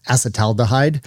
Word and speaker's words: acetaldehyde: And then it's going to convert that ethanol to, I'm going to mispronounce this, acetaldehyde, which is a toxic, did I say that right? – I think so.